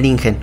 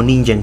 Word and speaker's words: Ningen: Comenzaré con el Ningen o Ninjen, como muchos lo pronuncian.